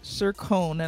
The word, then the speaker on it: Cercone: Welcome to the Author to Authority Podcast, and I have with me Jason Cercone, and hopefully I said that correctly.